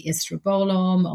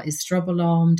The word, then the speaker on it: estrobolome: We know that there are certain microbes called the estrobolome or estrobolome, depending on which country you're in and how you want to pronounce it, are specifically there to help process and metabolize estrogen, for example.